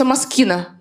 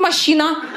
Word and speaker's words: Moschino: Я через 2 недели узнала, что правильно читается «маскина». Прикиньте, я 2 недели ходила, машину называла. Я в ресторане стул под нее просила. Я говорила: стул не убирайте, тут будет машина.